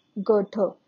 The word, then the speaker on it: Goethe: So the first writer to be studied from the world literature is Goethe, G-O-E-T-H-E, popularly known as Johann Wolfgang Goethe. So G-O-E-T-H-E is pronounced as Goethe.